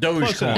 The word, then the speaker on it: Dogecoin: Dogecoin.